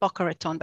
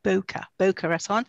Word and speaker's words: Boca Raton: Boca Raton. Well, I pronounce it Boca Raton, but here they say Boca Raton, which is very, very pretty.